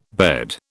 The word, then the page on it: bed: British English